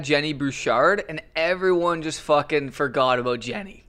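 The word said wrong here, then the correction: Genie: You forgot how to pronounce her name. It's Jeannie.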